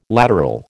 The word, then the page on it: lateral: American English